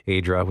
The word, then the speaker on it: Aydrah: That's how you can find it on Facebook if you look for at With Love Adra, which is spelled A-Y-D-R-A-H.